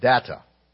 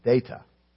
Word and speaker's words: Data: And as they are conversing, she continues to mispronounce his name. She keeps calling him Data. And every time she calls him Data, he corrects her and says, "No, it's Data."